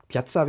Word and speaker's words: Piazza: Du hast äh, Piat Piazza wird da ausgesprochen, glaube ich. Piazza, genau, genau.